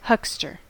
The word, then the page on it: huckster: American English